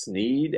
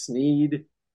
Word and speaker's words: Sneed: But it's pronounced Sneed as in seed.